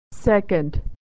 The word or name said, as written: second